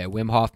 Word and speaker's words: Wim Hof: All right, so we've been talking about the Wim Hof. Is that how you pronounce it? – Yeah, Wim Hof method.